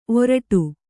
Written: ಓರಿಡು

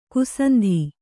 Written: ಕ್ಷಾಂತ

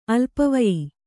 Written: ಅಲ್ಪವಯಿ

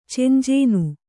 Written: ಚೆಂಜೇನು

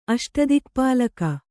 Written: ಅಷ್ಟದಿಕ್ಪಾಲಕ

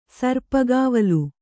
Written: ಸರ್ಪಕಾವಲು

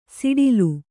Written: ಸಡಿಲ

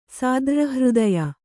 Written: ಸಾದ್ರ್ರಹೃದಯೆ

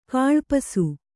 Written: ಕಳ್ಪಿಸು

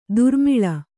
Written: ದುರ್ಮಿಲ

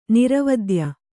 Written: ನಿರವದ್ಯೆ

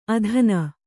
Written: ಅಧೀನೆ

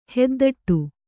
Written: ಹೆದ್ದಟ್ಟು

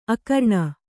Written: ಆಕರಣೆ

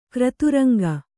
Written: ಕ್ರತುರಂಗ

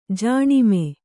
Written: ಜನ್ಮ

